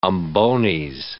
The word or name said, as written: umbones